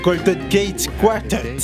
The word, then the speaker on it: Golden Gate Quartet: Non, Golden Gate Quartet.